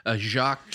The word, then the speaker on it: Jacques: Jacques Cloutier. – How do you spell that? – J A C Q U E S, like the proper Jacques.